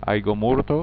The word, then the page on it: Aiga morta: Aÿguemourte